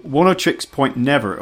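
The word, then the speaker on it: Oneohtrix Point Never: How am I pronouncing 10 Trix Point Never? Is that right? – Yeah, that's perfect. Right. – 10 Trix Point Never.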